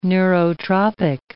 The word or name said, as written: neurotropic